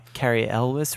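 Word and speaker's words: Cary Elwes: Carrie Elwes. – Elvis. – I think that's how you pronounce it. – All I'm right. Carrie Elwes.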